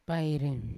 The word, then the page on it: pairi: Yawa